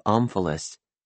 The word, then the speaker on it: omphalos: Full disclosure, I had to look up omphalos. You hit the first syllable, by the way, like you would an emphasis.